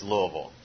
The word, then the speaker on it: Louisville: And that is how you say it, by the way. It's not Louisville or anything like that. It's Louisville.